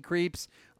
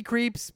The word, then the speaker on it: Krieps: We'll be talking with Vicky Krieps. She's got the best last name, by the way, and it's spelled K-R-I-E-P-S. Not like creeps, like, you know, Halloween.